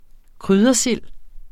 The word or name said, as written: kryddersild